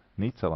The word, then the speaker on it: Nica: es nezinu, kā izrunājās Nica, Nica laikam, jā,